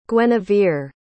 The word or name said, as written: guenevere